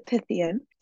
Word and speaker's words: Pythian: It is the Pythian.